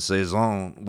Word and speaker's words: Cezanne: And he said there's a lot of artists that are like, and he compared Cezanne. Is that how you say it? – Yes, Cezanne. Of course, Cezanne.